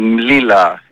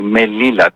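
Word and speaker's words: Melilla: Οι δύο πόλεις αυτές που είπατε, ή μάλλον πήγατε να πείτε, και θα τις προφέρω στην γνήσια προφορά των Μαροκινών. Η Μλίλα, Μελίλα τη λένε στα ελληνικά, και Σεούτα που τη λένε Σάπτα στα αραβικά.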